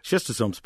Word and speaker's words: schistosomes: They have, go by the name schistosomes, if I've got the correct pronunciation. – You did very well. Yeah, schistosomes